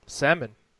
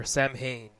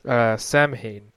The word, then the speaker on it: Samhain: It originates from the Celtic festival of salmon. No, not fish. Or Samhain, if you don't want to pronounce it like salmon the fish. But which would confuse a lot of people. But yes, Samhain.